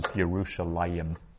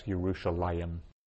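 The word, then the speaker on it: Yerushalayim (ירושלים): So we would pronounce that out as Yerushalayim. But this is how, without the yod, this is how it is always spelt in the Hebrew Bible. We read it as Yerushalayim.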